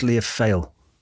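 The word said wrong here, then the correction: Lia Fáil: So L-I-A-F-A-I-L. But I did go, I did break the habit of a lifetime and I looked up the pronunciation of something. – Oh my God. – I did. And it's pronounced Leofoy.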